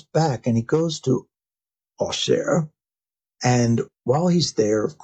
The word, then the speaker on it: Auxerre: So he is in a place called Ossher. Well, what is Ossher? A-U-X-E-R-R-E, right? So that is the French pronunciation.